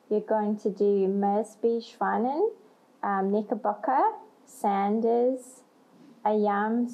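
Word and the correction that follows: Ayame: Socks. Is that pronounced the right way? – Ayami. – Ayami Socks, Tender Co, Papa Nui, and Lady White.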